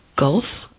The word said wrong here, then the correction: Guelph: Well, yeah, Guelph is the correct pronunciation.